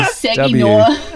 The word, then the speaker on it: Saginaw: Okay, let's do Josh Street from Sagina. – It's not a Sagina, but it almost is. S-A-G-I-N-A-W. – I was reading Saginaw.